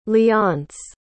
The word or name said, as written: leonce